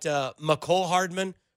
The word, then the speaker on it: Mecole Hardman: I know they talked about Mecole Hardman. We know that's how you say his name because our very own Brandon Kylie did a one-on-one with him, and that's how he told him to say his name.